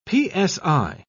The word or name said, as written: psi